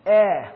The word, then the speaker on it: epsilon: It's an epsilon. Looks like an E. It's pronounced eh, like pet.